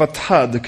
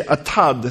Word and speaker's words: Atad: Then they came to the threshing floor of Atad. Could you say Atad? Atad. No, you can't say it like Atad. Atad. Atad. That's a little better.